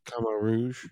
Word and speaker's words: Khmer Rouge: You know, Southeast Asia has gone through with the Khmer Rouge and with some of the, that's how you say it, right? – Mm-hmm. – Khmer Rouge. Yeah.